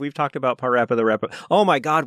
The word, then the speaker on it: Parappa: Parappa the Rapper.